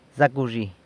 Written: Zac wzhi

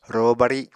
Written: ro bari

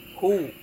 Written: hu

